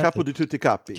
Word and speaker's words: capo di tutti capi: capo di capi. How do you say that? – Capo di tutti capi.